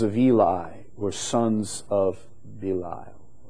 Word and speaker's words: Belial: Another name for the devil, Belial. Some pronounce it Belial. B-E-L-I-A-L.